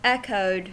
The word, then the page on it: echoed: American English